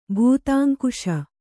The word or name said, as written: ಭೂತಾಂಕುಶ